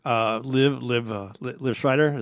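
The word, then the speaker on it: Liev Schreiber: I heard so much about it, and I'd like the actor, Liv Schreider. Is that, am I saying it right? – Yeah.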